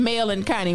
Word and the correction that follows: melancholy: How did somebody shout me out and say, this is how you pronounce melancholy? Because I'm going to be forever saying Mel and Connie, okay?